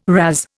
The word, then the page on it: razz: Indian